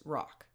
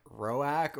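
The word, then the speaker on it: Roäc: Yeah, so in the audiobook read by Andy Serkis, he says rock, which I think is sort of like rock. – Oh, that's really neat. I always was thinking like Roak or Roke or something like that. – Roak is definitely how I would pronounce it just looking at it, but I think it's supposed to be more like rock.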